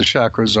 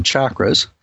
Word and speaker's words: chakras: So why don't you tell people first a little bit about what the chakras are, or as some people will pronounce it, chakras.